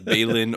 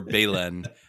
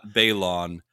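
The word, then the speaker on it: Baylan: But Patrick continues, for the love of the Force, his name is pronounced like Balin or Balen, not Balon.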